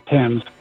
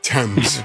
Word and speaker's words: Thames: That's the River Thames. – Yes! Way to go! – Even said it right. – He did. – Thames.